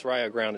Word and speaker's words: Rio Grande: This is Rio Grande, Ohio, pronounced Rio Grande.